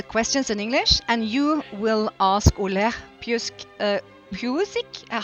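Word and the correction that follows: Psiuk: How do you say it? I want to. Psuk. It's psuk. It's just psuk. Oleg. Oh. Oleg? Oleg, yes, right. Perfect. And uh, the surname is Psuk.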